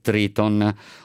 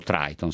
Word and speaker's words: Triton: Un piano del Viminale, scrive Repubblica, per sostituire le organizzazioni non governative con le navi di Triton, o Triton, se vi piace di più questa pronuncia.